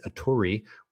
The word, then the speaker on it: @URI: I believe the pronunciation is Aturi, which is just delightful.